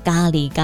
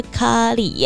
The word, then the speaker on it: 咖喱: 因为在节目当中一直讲咖喱咖喱的，讲到后来就被朋友给纠正说：“ 哎、欸，其实你知道那个发音啊是咖喱耶。” 然后全国广播的宝拉呢也说：“ 对啊，菜那个咖喱的咖啊，就跟咖啡的咖是同一个咖啊。